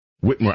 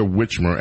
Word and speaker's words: Whitmer: Princess Di, I could see Gretchen, how do you pronounce her last name? – Whitmer. – I keep calling her Whitmer, and I'm sorry about that.